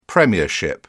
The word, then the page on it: premiership: British English